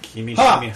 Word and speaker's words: Kimishima: It's ki, as in me, not like... – Yeah. Kimishima. – Not like Kimishima or Kimeshima or...